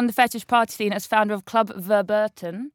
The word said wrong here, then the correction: Verboten: Is that how you say it? – Almost, yeah. – go on. How do you say it? – Verboten. Verboten.